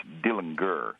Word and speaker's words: Dillinger: It's and the name is actually pronounced Dillinger.